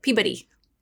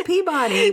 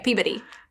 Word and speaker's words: Peabody: they pronounce it Peabody. – Peabody. – The Peabody Essex. – Yeah. And we're like, no, it's Peabody. We're from Arkansas. – Well, now you know how to say it, Peabody.